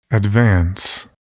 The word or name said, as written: advance